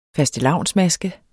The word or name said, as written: fastelavnsmaske